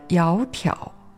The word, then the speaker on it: 窈窕: 窈窕两个字单独念或者慢读的时候都是三声，但根据汉语读音的规则，当两个三声的字合在一起念的时候，第一个字要念成二声。也就是窈窕，淑女的淑是美好、善良的意思。